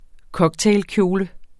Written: COCKTAILKJOLE

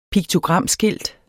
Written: piktogramskilt

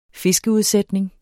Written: fiskeudsætning

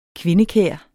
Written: kvindekær

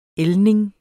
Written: ældning

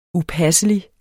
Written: upasselig

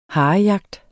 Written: harejagt

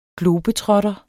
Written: globetrotter

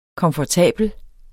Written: komfortabel

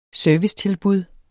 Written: servicetilbud

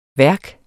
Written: værk

